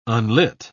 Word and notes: unlit: British English

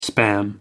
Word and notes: spam: American English

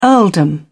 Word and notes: earldom: British English

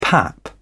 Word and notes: pap: British English

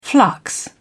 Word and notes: flux: British English

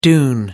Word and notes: dune: American English

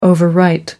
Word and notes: overwrite: American English

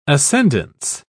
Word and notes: ascendance: British English

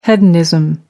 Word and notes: hedonism: American English

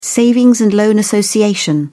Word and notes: savings and loan association: British English

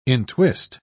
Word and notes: entwist: British English

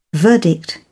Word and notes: verdict: British English

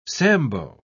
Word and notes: sambo: British English

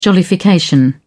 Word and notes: jollification: British English

